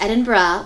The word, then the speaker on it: Edinburgh: And it's pronounced Edinburgh, which I learned.